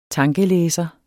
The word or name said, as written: tankelæser